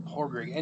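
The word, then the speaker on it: Pádraic: And Padraig. They don't pronounce the D. Padraig. It's like Porridge. It's not Padraig or Padraig or whatever. It's it's like Porrig.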